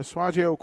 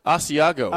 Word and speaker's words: Asiago: Asiago.